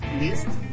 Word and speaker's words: Liszt: Esta gota de sabiduría nos la comparte Franz Liszt, ¿sí se dice bien así?